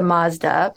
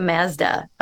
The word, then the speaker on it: Mazda: And that's the Mazda pronunciation. And you have some varieties of English where you can take it to the front, and that's the Mazda pronunciation,